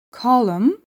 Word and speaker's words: column: Bir kelimenin sonundaki mn kombinasyonunda n harfi sessizdir. Him, autumn, column, damn kelimelerinde olduğu gibi.